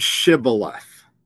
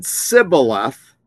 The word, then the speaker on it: Shibboleth: When they said no, they said to them, then say the word Shibboleth. And if this man said Sibboleth, for he could not pronounce it right, they would know that he was an Ephraimite, not from the tribe of Manasseh.